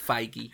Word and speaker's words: Feige: Feige. Kevin Feige. Feige. That's how he pronounces it, at least.